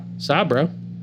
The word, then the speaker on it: Sabro: Remember, Sabro.